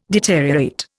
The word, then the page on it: deteriorate: Indian